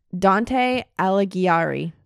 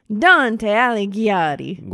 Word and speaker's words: Dante Alighieri: Dante Alighieri. – Let's try that. Can you do that, but more Italian? – Dante Alighieri.